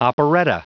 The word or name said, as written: operetta